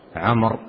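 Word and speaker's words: عمرو: فيما يتعلق بعمر يكتب في آخرها واو عمر، والواو لا تنطق وإنما تكتب دون نطق،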